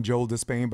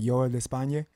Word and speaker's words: España: And Cuba, Joel de España, as the Americans would call him Joel de Spain, but I think I'm saying that right. Joel de España, is that correct? – Yep, that's right.